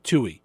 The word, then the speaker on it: Tuohy: It's like the number two in the letter E, Steve Tuohy.